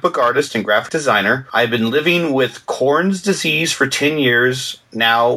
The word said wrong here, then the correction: Crohn's: I think it's Crohn's. I don't know if it's Crohn's. – Crohn's, yes. – Crohn's, okay. C-O-R-H-N-A. Yeah, Crohn's disease.